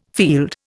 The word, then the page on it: field: Indian